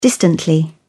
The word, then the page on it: distantly: British English